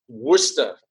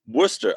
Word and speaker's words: Worcester: and the other one that gets everybody is Worcester, W-R-C-E-S-T-R. It looks like Worcester, but for some reason it's pronounced Worcester. – Worcester. Okay, because I knew I was saying that wrong, because I was just because it looks like Worcester sauce.